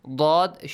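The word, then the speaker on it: ضاد: ضَاد shouldn't be pronounced like ذَال or ذَا. Okay? ضَاد. You can use the top part of your mouth with the tip of your tongue, or you can use the edge of your tongue, the two edges, okay? Either the right side or the left side with your, teeth, okay? The back ones.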